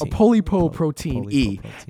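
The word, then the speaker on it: apolipoprotein E: And basically, so there are carriers of a, I'm not going to be able to pronounce this right, but a polyprotein E. And people that carry that. – It's like a polypoprotein. – A polypoprotein E.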